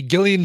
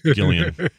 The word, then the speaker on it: Gillian: Hey, Gillian Jacobs pronounces it with a hard G from Community.